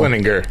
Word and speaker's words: Slininger: Slininger. – Slininger. So you do say Ger.